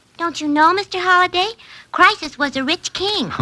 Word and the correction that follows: Croesus: Croesus, not Crisis, was a rich king.